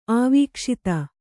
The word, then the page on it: ಆವೀಕ್ಷಿತ: kannada